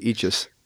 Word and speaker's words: Eachus: The fact that I'm having you on the show because I can finally say and pronounce it correctly, Eachus, which is really very simple. It's each and then us.